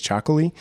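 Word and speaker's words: Txakoli: So for folks that listening out there who don't know what chocolate is, it's spelt with a TX, and that's the Basque language.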